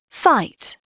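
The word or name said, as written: fight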